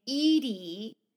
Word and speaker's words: Eaty: And they settle on the name Edie. E-A-T-Y, and they pronounce it like Edie, like the name Edie.